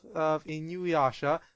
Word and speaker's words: Inuyasha: How do you pronounce that? Inuyasha? – Isn't it Inuyasha.